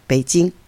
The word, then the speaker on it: Beijing: Prononcer correctement. Beijing.